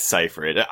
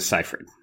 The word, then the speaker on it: Seyfried: I think it's Seyfried. I always say Seyfried. You put a G in her name somewhere. I don't know where that came from. But when I was watching the bonus features, Jason Reitman referred to her as Seyfried. – Okay, we will go with Seyfried. – Yeah. – Like it's spelled.